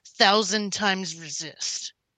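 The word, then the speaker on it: 1000xRESIST: Also, the adventure game Jordan looked at is pronounced 1,000 times resist. Okay. – Oh, not cross.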